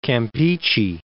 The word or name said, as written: Campeche